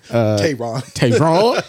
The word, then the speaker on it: Taron: I'm really starting to like Taron Egerton. – Oh, I said that wrong. I said it like he was black. – Tayron.